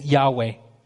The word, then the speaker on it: Yahweh: So generally, the commonly typical accepted way to pronounce it is Yahweh.